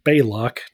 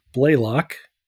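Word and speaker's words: Balok: So yeah, Baylock, and I've got to remember to pronounce it Baylock, not Blaylock.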